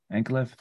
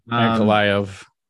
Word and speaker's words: Ankalaev: How do you say it's Ankalaev? Ankalaev. – Yeah. Ankalaev.